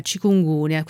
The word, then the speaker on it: Chikungunya: Chikungunya.